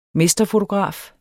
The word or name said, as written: mesterfotograf